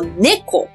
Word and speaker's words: 猫: 猫咪在日文里面，它汉字还是写作猫，但它的读音念作 neko。那或者是呢，我们也会叫它酿口。那个酿呢，就是呃，我们中文的猫咪是喵喵叫，英文也是喵喵叫，但是日文不知道为什么是酿酿叫，所以会酿酿叫的小东西，我们就叫它做酿口。好，那正式念法是叫 neko。